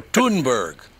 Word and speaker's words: Thunberg: Wouldn't her name be Thunberg? They wouldn't pronounce the H, would you? – That's probably right. – So Thunberg.